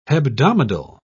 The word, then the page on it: hebdomadal: British English